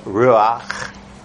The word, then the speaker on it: Ruach: which is pronounced Ruach, Yahweh, that's Hebrew for Holy Spirit.